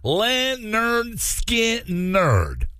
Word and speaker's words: Lynyrd Skynyrd: On this day, back in 1973, Lynyrd Skynyrd released their debut album, pronounced Lynyrd Skynyrd.